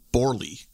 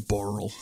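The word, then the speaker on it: Borle: And so I pronounced it as Borley, Christian Borley, because that's how it's spelt. – Yes. – And he very sheepishly and very kindly was like, it's actually Borel.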